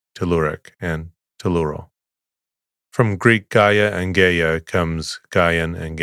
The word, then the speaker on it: Gaia: The Greek poetic name Gaia is rare, though the alternative spelling Gaia has become common due to the Gaia hypothesis, in which case its pronunciation is Gaia, rather than the more classical Gaia.